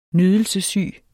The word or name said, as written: nydelsessyge